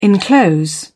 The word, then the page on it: enclose: British English